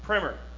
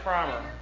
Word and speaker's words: primer: Primer. It looks like primer, but it's actually pronounced primer.